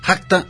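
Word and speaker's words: Rakta: Inmediatamente después nos fuimos a escuchar a Racta, esta agrupación brasileña que en realidad se pronuncia Racta, aunque se escribe R-A-K-T-A, y pues luego leemos cómo se escribe, ¿no?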